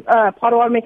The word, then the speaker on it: Pottawatomie: We are in Pottawatomie County, so we're going to be the Pottawatomie County chapter of Oklahoma.